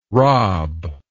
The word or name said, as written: raab